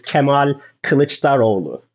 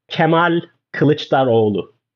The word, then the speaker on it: Kemal Kılıçdaroğlu: So his full name is Kemal Kılıçdaroğlu.